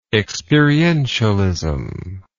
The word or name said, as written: experientialism